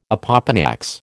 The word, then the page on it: opopanax: American English